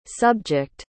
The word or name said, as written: subject